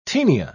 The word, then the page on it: tenia: British English